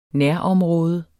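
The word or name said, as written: NÆROMRÅDE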